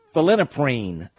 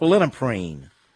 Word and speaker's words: phenylephrine: Phenylephrine. I think that's how you pronounce it. Phenylephrine.